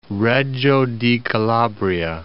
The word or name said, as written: Reggio di Calabria